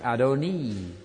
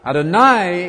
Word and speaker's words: Adonai: It's written A-D-O-N, letter I, but pronounced Adonai, not Adonai. Adonai is the word the Jews use for the Lord God. How are you going to remember it? Quite easily, because you all know the song about El Shaddai, da-da-da-da-da, that song. El Shaddai rhymes with Adonai.